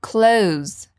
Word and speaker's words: clothes: E repara como a gente diz roupas em inglês, clothes. E a pronúncia é a mesma do verbo close, fechar. Repeat after me, repete comigo, close.